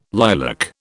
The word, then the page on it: lilac: British English